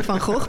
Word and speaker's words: Van Gogh: No, I'm saying Van Gogh because that is how you pronounce it. But that's obviously difficult for non-Dutch speaking people.